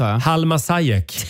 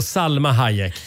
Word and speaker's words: Salma Hayek: Halma Sayek, säger många. Hon heter alltså Salma Hayek.